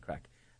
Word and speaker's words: craic: It's just not the but we pronounce it C-R-A-I-C, great crack, and which means really great fun.